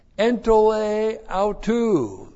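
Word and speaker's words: entole autu (ἐντολὴ αὐτοῦ): Point five, the norm and standard is entole autu. That's the Norman standard. Entole autu. E-N-T-O-L long E-A-U-T-O-U.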